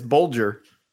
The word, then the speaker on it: Bulger: I googled it. It's Bulger.